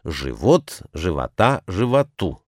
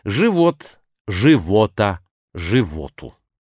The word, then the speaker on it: живот: Живот, живота, животу. А живот в смысле желудок на суффиксе. Живот, живота, животу.